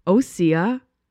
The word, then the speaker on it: Osea: I've been saying it right. Everyone said you're saying it wrong. No, I'm saying it right. Osea.